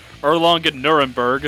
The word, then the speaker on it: Erlangen-Nuremberg: Erlangen-Nuremberg.